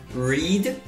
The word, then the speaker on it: read: E apesar de ser escrito igual read, a pronúncia é diferente quando essa palavra está no passado. Read no presente, read no passado.